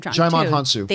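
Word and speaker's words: Djimon Hounsou: Jaimon Hansu.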